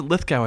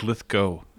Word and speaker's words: Lithgow: Yeah, I've always pronounced it Lithgow, I think, but it actually is Lithgow.